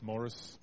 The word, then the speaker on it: Maurice: So I told the kids the other week, children in the church, Morris, M-A-U-R-I-C-E, so Maurice, but it's Morris. My father had a bit of a joke about that. I have an Uncle Morris, same name. It creates interesting conversations, so I'm not French, and it's spelt more rice, as the children say, more rice.